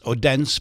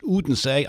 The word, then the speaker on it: Odense: It looks like it should be pronounced Odense, but it's actually pronounced Odense.